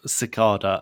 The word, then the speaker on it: cicada: Fred has the correct pronunciation of cicada. As far as I know, it's pronounced similar to the rice cracker brand, Cicada.